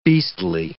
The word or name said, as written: beastly